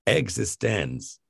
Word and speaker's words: eXistenZ: And they're very, again and again, you're supposed to pronounce it existens.